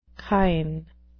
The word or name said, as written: kine